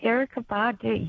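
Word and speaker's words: Erykah Badu: Erica Badu. – Ah, Erica Badu, yes.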